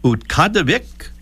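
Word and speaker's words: Utqiagvik: One is Utqadavik, Alaska, formerly Barrow, Alaska, but they've gone back to the original name there.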